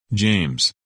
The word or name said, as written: James